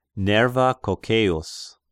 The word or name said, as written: Nerva Cocceius